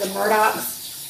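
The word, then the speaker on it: Murdaugh: Are following any of the news of these, they call them the Murdochs. – But they're not spelled the Murdochs, just by the way. It's spelled Murdaw.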